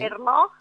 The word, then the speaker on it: Ernaux: Ernaux. – Sí. – Ernaux.